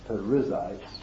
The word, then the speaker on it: Perizzites: Judah went up, and the Lord delivered the Canaanites and the Perizzites. That's where it should be pronounced. Perizzites.